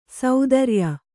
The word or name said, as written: ಸ್ಥಿರಾಯು